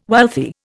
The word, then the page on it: wealthy: Indian